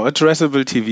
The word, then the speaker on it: Addressable TV: Wir reden über Addressable TV. Ne? Habe ich das richtig ausgesprochen? – Genau, Addressable TV, jawohl.